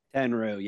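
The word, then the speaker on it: Tenryu: Is it Tenru? Kenru? – Tenru, yes. – Henru. – Tenru. – Kenru. Yeah, Tenru.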